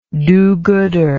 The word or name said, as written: do-goodery